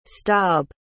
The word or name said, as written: stob